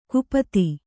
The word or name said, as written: ಕುಪತಿ